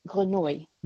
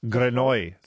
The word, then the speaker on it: Grenouille: If Jean-Baptiste Grenouille, was that a good pronunciation? – Is it Grenouille? I think if it's the French word, it's Grenouille. – Grenouille, thank you. Yeah. Because it is the French word, but not pronounced very well.